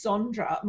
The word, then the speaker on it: Sondra: And because my name is Sondra my whole freaking life, everyone has corrected my name, very kindly corrected the spelling of my name and the pronunciation of my name to Sandra.